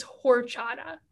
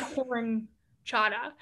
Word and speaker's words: horchata: And Christ is smoking their cigarette, looks at me and is like, it's horchata, not horinchata.